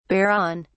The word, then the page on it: bear on: British English